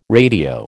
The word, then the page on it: radio: American English